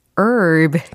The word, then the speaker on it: herb: 허브는 영어로 herb. 그렇죠. 철자에는 h로 시작하지만 묵음이었습니다.